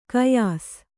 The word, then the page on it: ಕಯಾಸ್: kayās